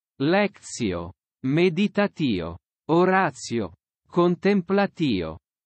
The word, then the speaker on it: oratio: Lectio, meditatio, oratio, and contemplatio.